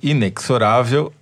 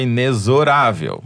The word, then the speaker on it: inexorável: No programa passado, a Yara Arruda nos ensinou, ou melhor, me ensinou, que inexorável é na casa da mãe Joana, que o certo é inexorável.